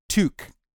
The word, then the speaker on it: toque: What I discovered and where I think the confusion lies is it would seem that in the UK, England and whatnot, they call a chef's hat a toque. However, in Canada, from every source that I've been able to find, from pronunciation guides to people who actually live in Canada saying the word, it is pronounced toque.